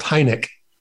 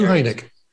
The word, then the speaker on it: Heinecke: But it's so my last name is pronounced Heinecke. – Heinecke. – Yeah, Stu Heinecke.